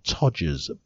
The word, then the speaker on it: todger: Even though numerous studies have shown that the size doesn't matter, many have researched how to make their todgers bigger. There's a fantastic British word, isn't it? Let me introduce to you the word Todger. – Rhymes with Dodger.